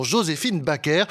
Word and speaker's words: Joséphine Baker: Oui, car c'est avec la prononciation française qu'elle voulait que l'on prononce donc son nom, Joséphine Baker, star du musical naturalisé française en 1937.